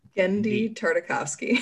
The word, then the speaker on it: Tartakovsky: Gendy Tartakovsky.